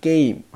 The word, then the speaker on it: 게임: 那么这边有一个发音啊，我想要强调一下哈，就是游戏那个单词啊，这个单词的话呢，写是写 game。啊，但是读的时候呢，要读 game 啊，读成一个紧音啊，game 就这个意思啊，稍微注意一下。